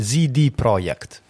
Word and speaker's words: CD Projekt: Eh, CD Projekt. CD Projekt. – Project? Sì. Anzi, la pronuncia ufficiale sarebbe ZD Projekt.